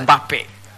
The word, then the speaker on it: Mbappé: Mbappé.